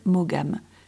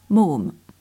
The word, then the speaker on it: Maugham: Somerset Maugham, ce nom qu'on écorcherait volontiers en français par Somerset Maugham. Mais non, il faut bien prononcer ainsi, Maugham.